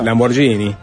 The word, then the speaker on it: Lamborghini: No, no, me parece que le das la pronunciación correcta, suntuosa, lujosa. Lamborghini. Exacto.